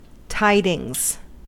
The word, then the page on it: tidings: American English